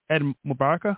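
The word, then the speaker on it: Eid Mubarak: Ed Mubaraka? How do you say that? Mubaraka? – Ed Mubarak. – There you go.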